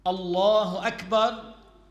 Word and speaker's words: Allahu Akbar: Not to say Allahu Akbar with ga. As you say garden, some people, if instead of saying Allahu Akbar, they say Allahu Akbar. This is invalid.